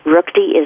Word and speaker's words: Ruchti: Ruchti is R U C H T I.